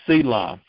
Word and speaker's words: sela: And the rock in the Hebrew there this time is spelled S-E-L-A, Selah, which means a high, smooth, elevated rock, which is a type of Jesus in his resurrection.